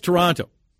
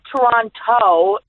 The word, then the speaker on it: Toronto: How was Toronto? – First of all, I learned it's not Toronto, it's Toronto. Mm. The second T is silent. Toronto.